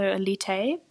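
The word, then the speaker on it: Élite: I say Elite because, I mean, it's a Spanish show, so surely the title would be in Spanish, so Elite, but it's spelt like the English word Elite.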